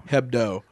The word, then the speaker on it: Hebdo: Is it Hebdo or Hebdo? – Hebdo. – Which I assume is pronounced Hebdo because The A-H, yeah. It's Hebdo.